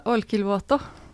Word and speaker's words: Olkiluoto: Olkiluoto. – Olkiluoto.